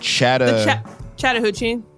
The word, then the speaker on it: Chattahoochee: Chattahoochee.